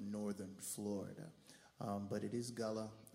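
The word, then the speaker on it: Gullah: what's that music? Goulash? It's Gulla. It's pronounced Gulla, and it is uniquely from the coastal islands of the East Coast.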